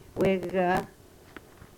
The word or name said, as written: wegah